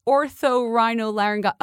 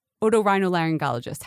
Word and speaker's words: otorhinolaryngologist: Oh, this is from the orthorhinolaryngologist. Oh, it's like what are they called? Otorhinolaryngologist.